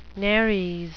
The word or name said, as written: nares